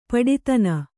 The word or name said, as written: ಪಡಿದನಿ